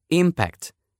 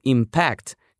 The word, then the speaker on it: impact: С другой стороны, можно было бы сразу и использовать глагол «impact». Это как раз и есть оказывать воздействие, ударять, влиять с ударением на втором слоге.